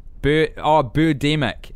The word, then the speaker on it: Birdemic: Birdemic is how you pronounce it.